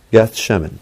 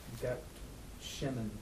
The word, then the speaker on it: Gethsemane: Gethsemane. – How do you say it? – Gethsemane. Yeah.